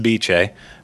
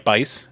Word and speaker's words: Bice: Unless people are just bamboozling me, they tell me it's pronounced Bice, but it's... – How do you say it? Well, how is it written? – Like Bice, as in Beau Bice.